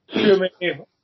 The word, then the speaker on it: Tchouaméni: There's a lot of rumours coming out of Liverpool just now that, is it too, I can't pronounce it, Tchouaméni? French midfielder? – Oh, I know what you mean, yeah. Got a lot of vowels in his name. He starts with T, C and H. – Tchouaméni.